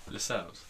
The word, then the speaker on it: Lascelles: I don't know how to pronounce his name. – Lascelles. – Oh. Well, seashells.